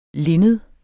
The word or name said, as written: linned